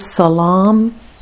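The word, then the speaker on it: salam (سلام): So with salam, the emphasis is on the last part of the word lam, lam.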